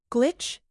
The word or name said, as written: glitch